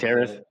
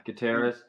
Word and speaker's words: Gutierrez: I don't know how to pronounce Gutierrez. – Yeah, Gutierrez. She's 24 years old, and according to this, she was described as inexperienced and green.